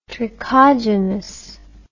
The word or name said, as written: trichogenous